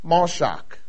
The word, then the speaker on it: mashach: The Hebrew word for anoint is mashach, and I'll spell that for you. It's M-A-S-H-A-C-H. M-A-S-H-A-C-H, pronounced mashach.